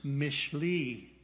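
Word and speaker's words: Mishli (משלי): The next book is Proverbs. It's pronounced Mishli in Hebrew. And it begins with the Hebrew letter Mem, which is the very middle letter of the Hebrew alphabet.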